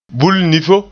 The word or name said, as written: bulnifo